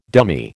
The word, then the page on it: dummy: American English